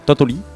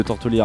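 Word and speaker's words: Tortolì: Tortoli, je prononce jamais le R.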